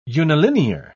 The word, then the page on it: unilinear: British English